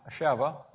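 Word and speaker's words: Sheva (שבע): I think, as has been noted at various times, the word seven in Hebrew, spelled Sheba, pronounced Sheva, a lot like our word seven, has two meanings.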